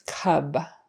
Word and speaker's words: cub: Cub, a short U, is a baby bear. I see a cab. Short A, the taxi. I see a cub. Short U, the baby bear. I see a cab. Short A, taxi. I see a cab. Short A, taxi. I see a cub. Short U, baby bear.